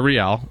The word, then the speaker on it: Real: They're Real.